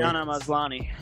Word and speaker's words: Tatiana Maslany: Tatiana Maslany.